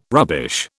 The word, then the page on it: rubbish: British English